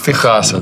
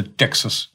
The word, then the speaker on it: Texas: Из Техаса, да. – Техас по-английски читается Texas, не Техас, нет.